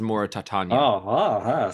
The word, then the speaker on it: Mauritania: I think it's pronounced Mauritania. – See? – Like Titania.